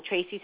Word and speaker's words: Tracey: And my Skype name is Psychic Tracy Brown, and Tracy spelled T-R-A-C-E-Y.